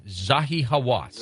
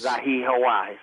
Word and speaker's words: Zahi Hawass: The woman that I was, that was my guide, the Egyptologist, she had studied under, I can't, correct me if I get the gentleman's, the doctor's name wrong, Zahi Hawass. – Zahi Hawass.